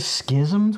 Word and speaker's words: schism: Before the schism took place, is that how you pronounce that word? – Yeah, it is.